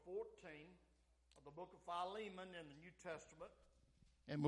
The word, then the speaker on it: Philemon: And I'm for years and years, I pronounced it Philemon, but then when I went to Bible college, I found out that's not how you pronounce it. It's Philemon. It's the book of Philemon, and it's right after Titus.